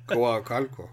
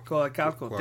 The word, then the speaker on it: Coacalco: They're from Coaco State of Mexico. Am I saying that right? I'm really bad with Spanish. – Whoa. – Right? It's kind of weird, right? – Coacalco. – Coacalco. Thank you, sir.